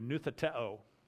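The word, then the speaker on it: nutheteo (νουθετέω): By the way, the word instruct one another is the word, the Greek word, let me pronounce it for you, nutheteo.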